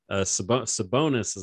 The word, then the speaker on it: Sabonis: Sabini. Sabonis is the last name, because I believe his dad is Arvydas Sabonis. – Well, that's exactly how it looks.